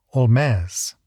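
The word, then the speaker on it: Holmès: In any event, it looks like English, is pronounced like French, and sounds vaguely like Spanish, Olmez.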